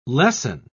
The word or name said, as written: lessen